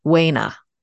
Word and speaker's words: Corca Dhuibhne: D H U I B N E. That's pronounced Wayna.